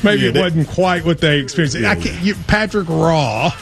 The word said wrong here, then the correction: Roy: Yeah, R-O-Y, but it's pronounced Wah.